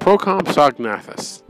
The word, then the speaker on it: Procompsognathus: They're called Procompsognathus. That's the correct way to pronounce it before I butchered it.